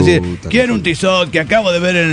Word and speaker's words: Tissot: Póngale, sí, sí, bueno, Tissot se dice Tissot y no Tissot. En ese caso se escribiría Tissote para que se pronuncie la T.